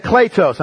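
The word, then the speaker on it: kletos (κλητός): Kletos. I know it looks like Cletus. You pronounce that kletos, okay?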